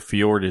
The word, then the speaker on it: fjord: It's spelled F-J-O-R-D-S.